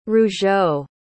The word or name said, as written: Rougeau